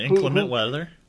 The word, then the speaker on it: inclement: So we are talking about now, how do you say inclement weather? Am I saying that right? – Yeah, inclement weather.